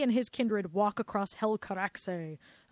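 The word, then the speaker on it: Helcaraxë: Hel Caraxe. – Caraxe. – It's H-E-L-C-A-R-A-X-E with the two dots.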